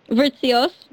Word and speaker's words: Vritsios: Vritsios.